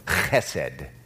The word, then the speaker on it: chesed: It's important that you pronounce it correctly. Chesed. Don't say chesed That's not, that would not be right. Chesed. Can everyone say that? Chesed. Very good.